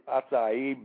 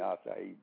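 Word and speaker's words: açaí: It's spelled what, like A-C-A-I? – Yes, A-I. – Uh-huh. – You know, it's pronounced in a Portuguese way, A-C-I-E.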